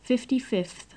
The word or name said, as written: fifty-fifth